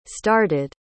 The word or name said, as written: started